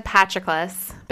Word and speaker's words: Patroclus: I said Patroclus because that's how Achilles kind of pronounces it in the book.